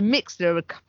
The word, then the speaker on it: Mixlr: But anyway, so Mixlr follows that same logic. So it's spelled M I X L R.